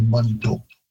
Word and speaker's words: Manitou: And again, also Manitou, all three syllables are being placed in the English pronunciation, whereas in Cree, it would be very common to sort of reduce that middle syllable right out of there. And Manitou, it sounds more like a two-syllable word.